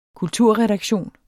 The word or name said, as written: kulturredaktion